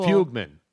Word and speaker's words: Fugman: No, Fugman. – Is it Fugman? – Fugman. It's spelled F-U-G-M-A-N.